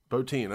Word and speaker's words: Bottin: But Rob Bottin, yeah. – Bottin, okay.